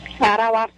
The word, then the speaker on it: Arauá: Arauá, Sergipe. – Arauá? Falei certo? – É.